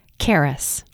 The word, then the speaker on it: Charis: Charis.